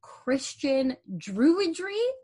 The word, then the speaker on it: Christian Druidry: she does Christian Druidry.